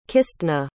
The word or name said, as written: Kistna